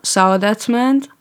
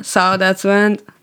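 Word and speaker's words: Saadatmand: Like, my name on Instagram is just Sonia Saudetsmand. It's just my name and my family name. Like, S-O-N-I-A, S-A-A-D-A-T-M-A-N-D.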